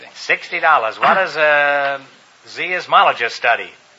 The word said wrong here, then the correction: seismologist: Seismologist, I believe.